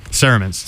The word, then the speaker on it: Cerements: Am I pronouncing it right? – Cerements. – Cerement. – That's what I would say.